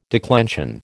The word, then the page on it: declension: American English